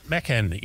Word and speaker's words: Macan: Macan, yes. Now, by the way, the Taycan has Turkish language roots and roughly means soul of a spirited young horse. No. Now, you said Macan.